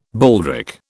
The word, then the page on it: baldric: British English